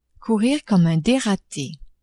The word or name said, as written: courir comme un derate